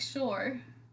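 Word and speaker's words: Schur: Are you a fan of Mike Shore? Do you know who Mike Shore is? – I know it's not spelled like Shore. – It's S-C-H-U-R.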